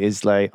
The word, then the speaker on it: Islay: And is the, you know, the peat is obviously a big thing in what I mispronounce for most of my life, but is actually called, I'm going to say Islay. – Islay.